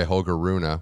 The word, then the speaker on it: Holger Rune: He's upset in that match in a barn burner by Holger Rune, which is the pronunciation that we're going with officially.